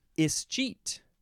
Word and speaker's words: ischeet: You can pronounce it with an is or an ish at the beginning. Ischeet or ischeet. It's subtle. It's a subtle difference, but it's there. It's just the word cheat with es at the beginning.